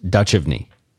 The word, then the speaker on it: Duchovny: You're thinking of David Duchovny. – Oh, no. He was in that TV show I didn't watch. – Pronounced Duchovny.